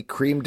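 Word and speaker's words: crème: It's rye whiskey, creme de cassis. Am I pronouncing that right? – Creme, but yes.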